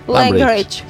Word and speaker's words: Langridge: És que mira, pronuncien superxungo, perquè Lambridge. – Jo t'he dit Lambridge. Bueno, però això és en anglès. En el meu... – En pinedenc? – Això. Fanella Langridge. – Langridge. Sí, correcte. – Langridge.